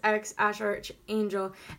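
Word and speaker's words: X Æ A-12: And, but it's pronounced X Ash Arch Angel.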